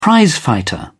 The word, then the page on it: prizefighter: English; British English